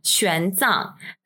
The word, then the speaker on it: Xuanzang: Today, as part of a special Chinese New Year episode, we are going to be discussing the 2016 film Xuanzang. That's X-U-A-N-Z-A-N-G. If it's a little difficult to pronounce, you can think of the X sound as an SH sound. So Xuanzang.